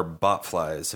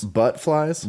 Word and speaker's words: bot flies: You know what this makes me think of are bot flies. Have you guys seen bot flies? – Butt flies? – Bot flies. – Spell it. – Oh, God. – B-O-T.